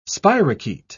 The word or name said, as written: spirochete